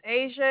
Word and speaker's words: Asia: Is about to be, my name is Asia.